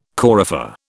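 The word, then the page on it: coryphée: British English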